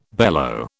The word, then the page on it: bellow: British English